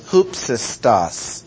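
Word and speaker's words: hupsistos (ὕψιστος): Most High is the translation of one single Greek word, which is hupsistos. And I guess we could spell it H-U-P-S-I-S-T-O-S.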